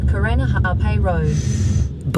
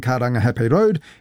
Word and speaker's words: Karangahape: That was TVNZ's Māori Issues reporter Te Aniwa Huri Hanganui driving around Auckland and unimpressed with the navigation voice on the Google Maps app mispronouncing Karangahape Road and other places with Māori names.